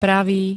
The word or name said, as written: pravý